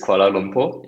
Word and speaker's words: Kuala Lumpur: The city is Kuala Lumpur. – It's correct. Well, you spelled it correctly. Yep. – Oh, how do you say it? – Yeah, it's Kuala Lumpur.